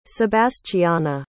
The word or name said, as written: sebastiana